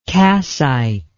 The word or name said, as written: kassite